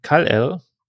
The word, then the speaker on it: Kal-El: Bevor ihr Planet Krypton explodiert, schicken Jor-El und Lara ihren Sohn Kal-El, wahrscheinlich ist es falsch ausgesprochen. Nee, passt.